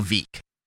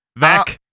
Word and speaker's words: Veeck: Many in the Chicago and national media believe that Shane's hiring was yet another publicity stunt by White Sox owner Bill Veeck. – Veeck. It's Veeck. – Veeck.